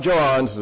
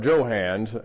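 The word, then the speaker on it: Johanns: Mike Johans is or Joe Hans. Am I saying it wrong? I thought it was Hans, but it's Hans. Hans. Get your hands off of there.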